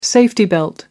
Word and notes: safety belt: American English

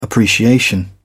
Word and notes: appreciation: British English

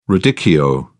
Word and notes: radicchio: British English

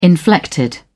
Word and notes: inflected: British English

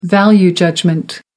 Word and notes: value judgment: American English

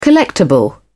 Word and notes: collectable: British English

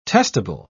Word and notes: testable: British English

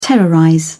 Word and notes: terrorize: British English

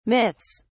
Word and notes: myths: British English

